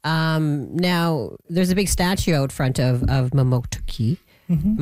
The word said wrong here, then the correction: Mamotokati: Am I saying that right? Mamotoki? – No, Mamotokati.